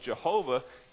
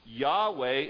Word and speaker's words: Jehovah: The problem with Jehovah is that the J sound doesn't exist in the Hebrew language. So if anything, we're certain that that's not his name. That's not the way you pronounce it.